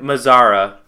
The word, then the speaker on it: Mazara: Mazara.